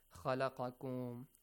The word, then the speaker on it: خَلَقَكُمْ: U'budu Rabbakum alladhi khalaqakum. So the pronunciations of the letters Dhal, Kha, Qaf. Okay? Kha, La, Qa, Kum. Kha, La, Qa, Kum. Kha, La, Qa, Kum. Alright? Make sure it's a heavy.